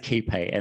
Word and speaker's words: Quay: It's essentially something we're promoting together with with PlaceOS, and so we pronounce it KeyPay. And the, the quay part, we founded this business, Steve and I, in Circular Quay in Sydney. So quay is is like as in like where boats dock.